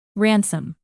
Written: ransom

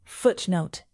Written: footnote

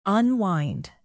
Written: unwind

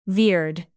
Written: veered

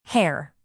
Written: hare

